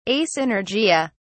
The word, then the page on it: asynergia: British English